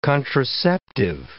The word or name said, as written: contraceptive